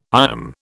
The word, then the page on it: IOM: British English